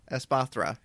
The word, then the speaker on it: Espathra: I think, I don't know the correct pronunciation. I've been saying Espatra. – Espathra, yeah.